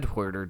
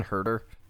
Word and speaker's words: Huerter: So that's only going to further the role that Huerter is going to play. And also apologies to all of our Atlanta fans. You know, I said Huerter to start, and that just felt wrong. It's Kevin Huerter.